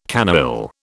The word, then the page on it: canaille: British English